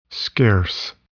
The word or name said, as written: scarce